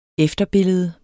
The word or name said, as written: efterbillede